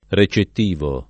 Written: recettivo

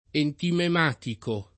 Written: entimematico

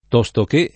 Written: tostoché

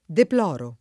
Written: deplorare

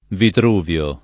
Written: Vitruvio

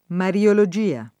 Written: mariologia

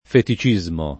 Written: feticismo

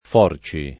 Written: forci

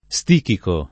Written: stichico

